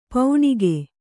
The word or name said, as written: ಪೌಣಿಗೆ